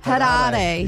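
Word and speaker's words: Harare: Which landlocked African country has Hariri? I don't know if I'm saying that right. – No. – It sounds like you are. – Hariri.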